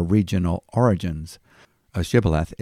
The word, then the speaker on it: Shibboleth: Ephraimites were not able to pronounce the sh sound, as in shoe. Their pronunciation would sound like Sibboleth.